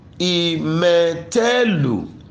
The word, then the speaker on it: imetelu: No Ibo, the pronunciation of this word in both the written and spoken forms is the same, which is I me te lu.